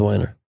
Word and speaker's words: Weiner: You say Weiner, not Weiner? Is there a reason you do that? Not you don't say Anthony Weiner? – It's pronounced Weiner? – Yeah.